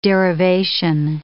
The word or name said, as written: derivation